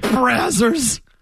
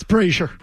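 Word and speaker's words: Brasier: Is that really how you pronounce that? – No, it's either Brazier or... – It's Brazier. – Brazier. – It's like... – I go with Jimmy's. – Think of it as Frazier, but with a B.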